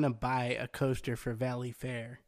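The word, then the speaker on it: Valleyfair: Valley Fair has announced... – Hey, you didn't say it right. – Valley Fair! – There you go.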